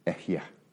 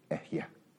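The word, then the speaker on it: Ehyeh (אֶהְיֶה): This one we do know exactly how to say it. It's an awkward little word, Ehyeh. There's a couple of H's in it, Ehyeh.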